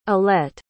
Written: allete

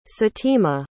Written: satima

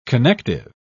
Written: connective

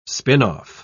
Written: spin-off

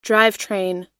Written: drivetrain

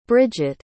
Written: Brigette

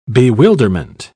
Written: bewilderment

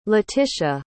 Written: Laetitia

